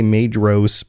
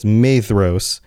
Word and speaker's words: Maedhros: My only qualm is the way you pronounce names like with the DH in them, like I say Maedhros, but you're saying the DH is pronounced with like a TH, so it's Maedhros.